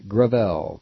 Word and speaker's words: Gravel: Just Mike Gravel, spelled like gravel, G-R-A-V-E-L.